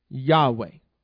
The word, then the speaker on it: Yahweh: Now, we know that the Father's name is Yod, He, Vav, He, pronounced Yahweh.